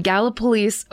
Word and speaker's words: Gallipolis: So this story took place in and around the cities of Point Pleasant, West Virginia, and Gallipolis, Ohio, which I can't believe that's the way it's pronounced when it looks exactly like Gallipoli.